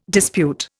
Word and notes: dispute: Indian

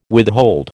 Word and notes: withhold: American English

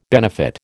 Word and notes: benefit: American English

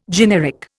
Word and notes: generic: Indian

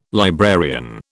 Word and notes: librarian: British English

